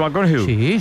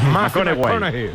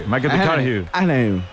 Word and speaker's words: McConaughey: ¿Matthew McConaughey? – Sí. – Hay que ir corrigiendo la pronunciación. – McConaughey. – McConaughey.